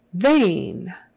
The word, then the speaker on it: vein: Vein, vein. E-I in this case is pronounced like a long A. They, vein, vein, vein. Make sure you get a V and not a B.